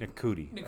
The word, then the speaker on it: Ncuti: N-C-U-T-I. Nakuti? – Nakuti. Okay.